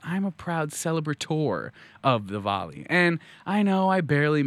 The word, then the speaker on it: Diwali: Diwali. – It was Diwali.